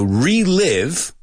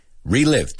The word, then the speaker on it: relive: There's another one where you have to really pronounce that I, not like E, right? So relive, relive.